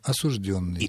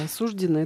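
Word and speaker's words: осуждённый: С точки зрения норм литературного языка, правильно только осужденный.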